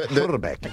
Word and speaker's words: Hrbek: How do you pronounce the it's Kent Hrbek.